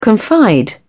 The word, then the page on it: confide: British English